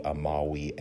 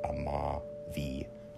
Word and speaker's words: amavi: So amo, amare, amavi, amatum, because V's are pronounced as W's, so it would be amavi and not amavi.